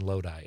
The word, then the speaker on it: Lodi: I never know how to say this because I've heard it pronounced Lodi and Lodi. It's Lodi, right? Yeah, yep.